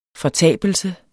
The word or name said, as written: fortabelse